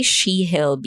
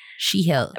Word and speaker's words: Shegil: So thank you so much to our new patrons, Henny, and I want to say Shehil, Bishi. It's spelled S-H-E-G-I-L. Like, Puede. Shehil.